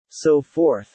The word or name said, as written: so forth